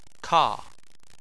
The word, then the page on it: ka4: chinese